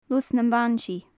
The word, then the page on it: lus nam ban-sith: gaelic